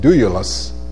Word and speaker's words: doulos: The Greek word doulos, and that's the way you pronounce it, doulos, or doulos, means bond slave, literally one tied to another.